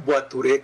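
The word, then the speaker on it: voiturette: La palabra Boituré, que se escribe Boiturete con doble T, es una palabra francesa para describir un automóvil.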